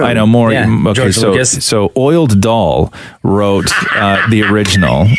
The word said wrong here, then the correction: Roald Dahl: Roald Dahl. – Oiled Dahl. What am I not getting? Say it again. – Roald Dahl.